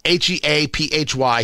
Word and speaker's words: Heaphy: Former federal prosecutor Tim Heaphy, H E A P H Y, Heaphy, I believe it's pronounced,